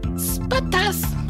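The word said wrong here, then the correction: Spaihts: It literally says pronounce Spates right after that.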